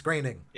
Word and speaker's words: Groening: It looks like it says Groening, but it's Groening.